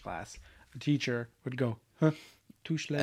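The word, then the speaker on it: touché: Touche, bitch. – It's pronounced touche.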